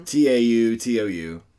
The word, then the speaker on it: Tautou: Audrey Tautou. – Tautou? Is that how you say it? I've never known. – Tautou.